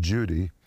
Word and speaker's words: Cudi: Actually, it's spelled C-U-D-I, but it's pronounced Judy.